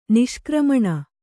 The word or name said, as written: ನಿಷ್ಕ್ರಮಣ